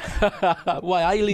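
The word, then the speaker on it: Waialae: Just imagine you're a Geordie saying it. – Wailea.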